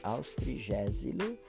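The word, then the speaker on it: Austregésilo: No encontro com o mestre dessa edição, o presidente Queda faz referência ao Dr. Austrégilus de Ataíde, um nome difícil de pronunciar, mas é, é isso aí, Austrigésilo de Ataíde, né?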